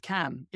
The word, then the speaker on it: Cannes: Cannes, I hate. Cannes is a town in France, in northern France. And then Cannes. I mean, Cannes. – You know. It's Cannes. It's just a tin of beans, a can of beans.